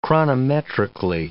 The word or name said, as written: chronometrically